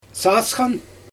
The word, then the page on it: sasxan: Xyzyl